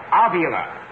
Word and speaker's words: Avila: Avila.